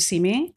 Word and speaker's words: Simi: It's called Come See Me, right? – But it's spelled S-I-M-I for Simi Valley.